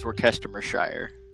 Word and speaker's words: Worcestershire: It's Brian, the correct pronunciation is Worcestershire.